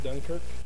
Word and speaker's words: Dunkirk: Derek Dunkirk. – Can you spell it for, please? – D-U-N-K-I-R-K.